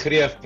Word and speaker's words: Greeff: Yeah, it's Greeff, but Greeff is good enough.